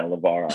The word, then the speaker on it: Luvara: Did I say your last name right? – You nailed it, yeah, Luvara.